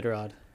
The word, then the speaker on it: Iditarod: The Iditarod.